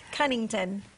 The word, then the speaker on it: Cunnington: So, Havilah, I want to say your last name right. And it's Cunnington. – Cunnington. – Yep. – I always want to call her Cunningham.